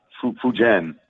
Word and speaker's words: Fujian: I believe you said it's Fuyan. Is that, am I saying that right? The Sturgeons? – Yeah, yeah. Fujian. – Fujian, actually. Okay, the Fujian Sturgeons.